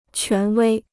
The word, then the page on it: 权威: quán wēi